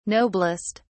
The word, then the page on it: noblest: British English